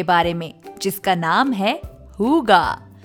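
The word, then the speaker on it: hygge: इसकी स्पेलिंग थोड़ी अलग है but it is called हुगा so the spelling is H Y जी जी ई अब आप सोच रहे होंगे इसको हुगा क्यों कहते हैं Well, this is a Danish concept and it is pronounced in a Danish way.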